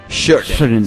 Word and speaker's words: Sheraton: Sheraton. Se dice así. Sheraton. Y bueno, lo primero que hace, por ejemplo, no toma alcohol.